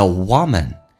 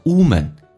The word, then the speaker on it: woman: The woman. 首先，这个单词很多人会发成 woman，其实它发的是个短元音 a，打开口腔 a， woman. You got me?